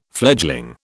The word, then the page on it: fledgling: British English